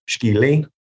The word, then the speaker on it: skili: We have it would really be pronounced skili in Cherokee,